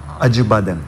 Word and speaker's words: Acibadem: Is it Acibadem? How you pronounce it? The hospital. – Acibadem. – Acibadem. Yeah. Acibadem.